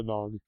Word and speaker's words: Nyong'o: Well, I like the casting of Lupita Nyong'o.